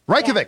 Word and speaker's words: Reykjavik: Reykjavik. Yeah. That is how you say it.